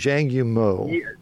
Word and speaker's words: Zhang Yimou: Zhang Yimou? Zhang Yimou.